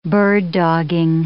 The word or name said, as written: bird-dogging